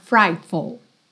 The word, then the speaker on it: frightful: Frightful. E atenção a esse sufixo F-U-L que você não tem full, tá? É full, full, frightful, frightful. A sílaba tônica tá no fright, tá?